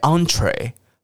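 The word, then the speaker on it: entrée: 这个字从法文来，很多法文 en 开头的字都念成 ang ang ang 的声音，因为有时候我们看到 en 会很自然念成 n，对不对？好，比方说像套房、雅房，那个叫 ensuite，ensuite。好，比方说像这个开胃菜 entree。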